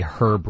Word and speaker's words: herb: I pronounce the H, so I would say herb roasted.